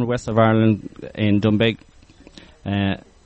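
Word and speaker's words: Doonbeg: It's D-O-O-N-B-E-G. I would call it Dunbeg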